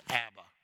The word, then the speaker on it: Abba: Some pronounce it Abba. I pronounce it Abba.